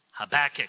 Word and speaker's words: Habakkuk: Our text this morning comes from the Old Testament prophet near the end of the Old Testament, Habakkuk, H-A-B-A-K-K-U-K, Habakkuk, if you want to say Habakkuk, or put your emphasis on some other syllable, you'll be right.